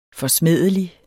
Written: forsmædelig

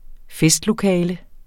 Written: festlokale

